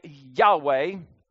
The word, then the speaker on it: Yahweh: So how do you say God's name? Well, let me explain this just real quick to kind of help you here. So here's God's name right here. And we say Yahweh.